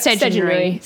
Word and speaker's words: Sedunary: S-E-D-U-N-A-R-Y. – Sedgenary. Yep, Sedgenary. Sedgenary is how you say it.